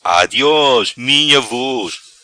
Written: Adios minha vos

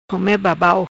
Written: Come Babau

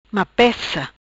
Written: Mapeca

